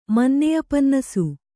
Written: ಮನ್ನೆಯಪನ್ನಸು

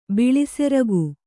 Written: ಬಿಳಿಸೆರಗು

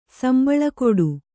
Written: ಸಂಬಳಕೊಡು

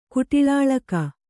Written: ಕುಟಿಳಾಳಕಿ

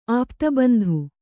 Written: ಆಪ್ತಬಂಧು

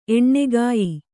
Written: ಎಣ್ಗಯ್

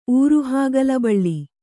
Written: ಊರುಹಾಗಲಬಳ್ಳಿ

